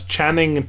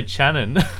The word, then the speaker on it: Channing: It's Channing Tatum. – Channing. I always say Channon.